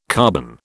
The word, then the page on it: carbon: British English